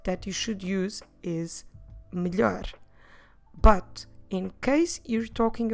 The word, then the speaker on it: melhor: And that different word in Portuguese is the word melhor. Melhor. Como se escreve melhor? M-E-L-H-O-R.